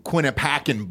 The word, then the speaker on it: Quinnipiac: There's literally the pronunciation typed out here, and you still said it wrong. – Quinnipiacan Balls. – Quinnipiac. – Is it Piac? – Look inside of the fucking parentheses.